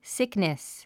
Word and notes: sickness: síknis; スィックネス

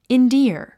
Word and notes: endear: indíə r; インディア

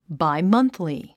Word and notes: bimonthly: baimʌ́nθli; バイマンスリィ

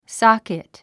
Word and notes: socket: sάkit; ソォキット

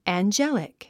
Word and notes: angelic: ændʒélik; アンジェリック

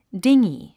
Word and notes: dinghy: díŋgi; ディンギー

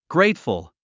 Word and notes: grateful: gréitfəl; グレイトフル

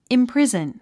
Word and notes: imprison: imprízn; インプリズン

